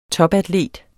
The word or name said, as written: TOPATLETER